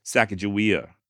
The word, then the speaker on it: Sacagawea: And the Limhi Shoshone do maintain that her name is indeed pronounced Sacagawea, just like I'm saying.